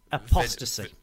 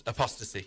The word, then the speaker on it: Apostasy: Apostasy. – Videos. – Apostasy. – Oh yes, apostasy. – And I said it right first time.